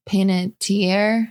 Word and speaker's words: Panettiere: Hayden uh, Panettiere.